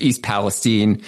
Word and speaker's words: East Palestine: Uh, I do have to shout out chat right now very quickly, who is uh, calling me out for mispronouncing Palestine. – Yeah, that's right. Yeah. I wasn't going to say anything, but yeah, he, the person in the chat is absolutely right. It's East Palestine.